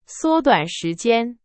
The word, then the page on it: 缩短: Chinese